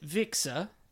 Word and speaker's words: VXR: Yeah, you sort of pronounce it Vixer.